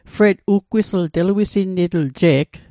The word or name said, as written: uggwisl